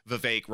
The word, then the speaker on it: Vivek: Vivek never recovered, and it saddens me deeply because up to this point, I have really enjoyed Vivek rhymes with cake,